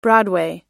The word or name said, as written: broadway